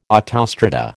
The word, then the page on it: autostrada: American English